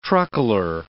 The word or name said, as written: truckler